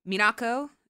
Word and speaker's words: Minako: Minako, is that me saying that right? – Mm-hmm.